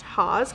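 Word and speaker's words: Haus: But I think it's pronounced Haas because it's H-A-U-S.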